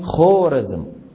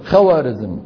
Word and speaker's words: خوارزم: فحتى يفرقوا حتى ما يقرأها الإنسان خورزم، خو بالواو العربية، يضعون بعد الواو ألفا تكتب ولا تلفظ، بمعنى انتبه هذه خورزم. بيجي واحد غشيم مثل حكايتي شو بيقرأها؟ خوارزم. وبيقول لك العلامة الخوارزمي، لك يا عمي ما في خوارزم بالدنيا، في ايش؟ خورزم وخورزمي.